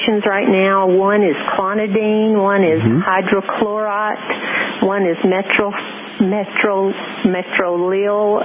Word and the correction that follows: metoprolol: I can't pronounce it, M-E-T-O-P-R-O-L-O-L. – It's called metoprolol.